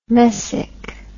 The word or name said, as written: messick